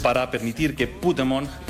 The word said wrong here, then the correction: Pokémon: El poder que hay en Pokémon.